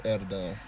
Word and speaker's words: Adidon: Bro, why are these things The story of Adidon. That's how it's pronounced. Yeah. Cause the shit, the Adidon right is supposed to be a play on, you know, Adidas, the ADI, then his son Adonis. Adonis.